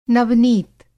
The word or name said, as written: नवनीत